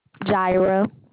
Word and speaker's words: Jireh: Jaira. – And will you spell that, please? – J-I-R-E-H. – J-I-I-E-H.